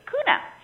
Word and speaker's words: Kauna: And I also would like my entry into the pronunciation of K-A-U-N-A to be, well, phonetically I want to say Kauna, but I am going to say Kuna.